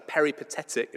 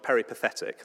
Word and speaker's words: peripatetic: And for those of you that know anything about music, there are music teachers that are peripatetic. Is that right? Have I said that right? Music teachers, not peripathetic. That's something different. Peripatetic music teachers.